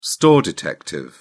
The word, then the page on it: store detective: British English